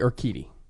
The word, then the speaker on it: Urquidy: I think my fave is probably Urquidy.